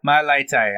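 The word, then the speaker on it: Mailata: Then you also are going to have, I'm going to butcher this guy's name, but the rugby player, Malatai. – My Laitai.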